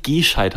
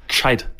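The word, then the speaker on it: gscheit: Nicht gescheit haben, sondern gescheit. – Gescheit. Aber auch geschrieben mit G. Und also der Buchstabe G einfach nur und dann das Sch sofort. – Ja, gescheit, ja. – Gescheit. So benutzt mal Buchstaben nicht, München. Du kannst nicht das G und dann sofort machen E zwischen, damit man es aussprechen kann.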